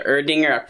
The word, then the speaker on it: Erdinger: Okay, well then let's wrap it up with the final review of the Erdinger Oktoberfest. What do we think? – Pronounced Erdinger.